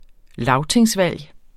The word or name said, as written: lagtingsvalg